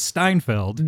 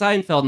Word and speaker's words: Seinfeld: It's called my dad's mispronunciation, Steinfeld. – Oh, really? So everyone mispronounces it as Steinfeld. – No, it's pronounced Seinfeld. My dad.